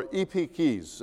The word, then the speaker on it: epieikes: The Greek word in the Bible, which is translated gentle, is epikides, or epikes, actually. In English, we would spell it E-P-I-E-I-K-E-S, all right?